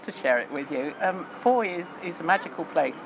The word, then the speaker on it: Fowey: And Foy, you might not know, it's got a strange spelling. It's spelled F-O-W-E-Y. And doesn't look like you pronounce it as Foy, but we rhyme it with joy.